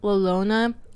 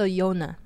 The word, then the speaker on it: La Llorona: Well, it's not La Llorona. It's... So the two L's is pronounced like a Y. So Yona.